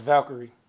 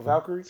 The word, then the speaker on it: Valkyrie: Stop. It's Valkyrie. – It's actually not. They actually tweeted us. – Yeah, they tweeted us and said we were saying it right.